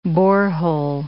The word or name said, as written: borehole